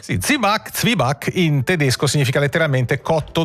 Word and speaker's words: Zwieback: Si scrive Zwieback, scritto con la V doppia e tutto il resto, ma si sente spesso dire Zibac, scritto proprio così anche. – Zibac. – Molti lo scrivono così, Zibac. Z-I-B-A con l'accento C. – Esatto, la pronuncia si semplifica, si ticinesizza, perde un po' di spigoli tedeschi e diventa Zibac, come hai detto tu.